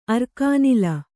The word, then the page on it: ಅರ್ಕಾನಿಲ: arkānila; Kannada